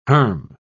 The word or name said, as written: herm